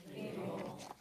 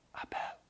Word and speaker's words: Abel: Say it out loud for a minute. Abel. Okay, now I want you to say it like this. Abel. Okay, that's how it's pronounced in Hebrew.